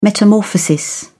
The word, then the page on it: metamorphosis: British English